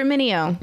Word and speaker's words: Mineo: I even said earlier, it's Mineo, right? – You said it correctly, yeah.